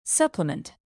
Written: supplement

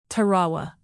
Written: Tarawa

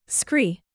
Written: scree

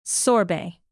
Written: sorbet

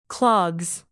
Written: clogs

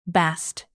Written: bast

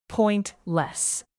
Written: pointless

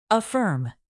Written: affirm

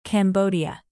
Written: cambodia

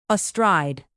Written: astride

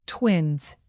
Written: twins

